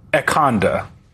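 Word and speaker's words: ecanda: Ekanda. – Ekanda? – Yeah, like Wakanda, but Ekanda.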